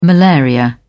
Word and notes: malaria: British English

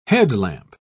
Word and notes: headlamp: British English

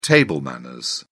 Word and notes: table manners: British English

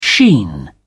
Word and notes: sheen: British English

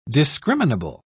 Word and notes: discriminable: British English

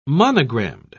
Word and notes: monogrammed: British English

